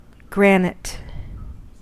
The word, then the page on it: granite: American English